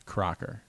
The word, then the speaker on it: Kracker: Tom Cracker, last name spelled Kracker. Pronounces his name Crocker.